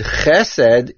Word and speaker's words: chesed: The word chesed follows the general rule of segoilim, that is, if the last two syllables of a word both have a segoil, then the word is mil'el, on the first segoil.